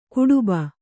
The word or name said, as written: ಗಡುಬ